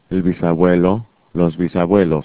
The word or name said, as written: bisabuelo